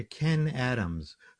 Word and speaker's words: Kenn: And I'll just mention that story Spine, the credit goes to Ken Adams, who wrote a book called How to Improvise a Full-Length Play. And it's K-E-N-N-A-D-A-M-S. So it's two N's, one D, but the second N in Ken is silent. So K-E-N-N Adams.